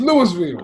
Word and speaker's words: Louisville: Why is there S in the word? – Come on, man. You know stuff is pronounced different everywhere. – that really bothers me. I know for Chicago. Illinois S is silent, but the Illinois S at the end of the word. The Illinois West, the end of the word. Like Lewis, it should be Louisville. It's Louisville.